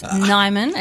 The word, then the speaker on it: Nijman: So close. Nijman. It's Nijman. A Dutch name, and the IJ is pronounced as a Y.